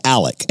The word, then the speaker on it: Alex: And then at the actual funeral itself, according to a couple of different podcasts that I went through, Alex Murdaugh, I'm sorry, I keep saying Alex because it's an X, but it's... – It's spelled A-L-E-X. – But it's pronounced Alec. Everybody, Alec his Murdaugh. Family members that I've seen and heard, they say Alec.